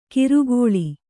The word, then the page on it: ಕಿರುಗೋಳಿ: kannada